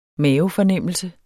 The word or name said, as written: mavefornemmelse